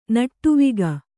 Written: ನಟವಿಗ

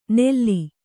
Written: ನೆಲ್ಲಿ

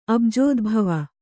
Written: ಅಬುಜೋದ್ಭವ

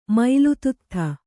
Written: ಮೈಲುತುತ್ಥ

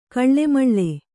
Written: ಗಾಳುಮೇಳು

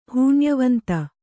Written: ಹೂನ್ಯವಂತ

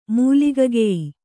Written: ಮೂಲಿಗಗೆಯಿ

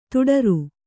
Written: ದಡಾರ

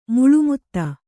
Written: ಮೇಲ್ಮಿತಿ

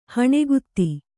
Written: ಹಣಗಾತಿ

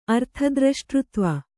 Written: ಅರ್ಥದ್ರಷ್ಟೃತ್ವ

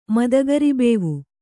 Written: ಮದಗರಿಬೇವು

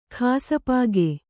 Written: ಕ್ಷಪಕ

